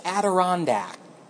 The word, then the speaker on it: Adirondack: The word was Adirondack, as in the Adirondack Mountains. I'd never seen it written down before. I've heard it, but I've never seen it in written form before. And I said, Adirondack.